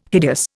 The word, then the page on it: hideous: Indian